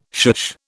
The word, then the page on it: shush: British English